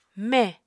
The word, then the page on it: me: Apatani